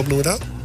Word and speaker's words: Bloemendaal: Want jullie zitten op de muziekschool Bloemendaal, zeg ik dat goed?